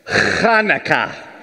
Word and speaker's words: Hanukkah: Number one, the holiday is pronounced Hanukkah. There you go. Not not Hanukkah, not Chanukah. To really get the authentic pronunciation, and I'm going to work with you on this, there's a letter in Hebrew that isn't in our language, chet. And chet comes from deep in the throat and chest.